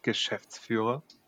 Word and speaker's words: Geschäftsführer: If you pronounce it in German, it's Geschäftsführer.